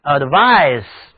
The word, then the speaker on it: advice: 那么名词劝告读音是 advise， CE 组合起来是清辅音 s。汤姆接受了我的建议到医院去了。 He accepted my advice.